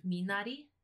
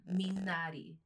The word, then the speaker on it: Minari: Minari. – One more time. – Minari.